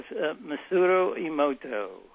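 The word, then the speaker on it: Masaru Emoto: talk to us a little bit about Dr. Is it Masura Emoto? Is that how you pronounce his name? – Yes, Masura Emoto.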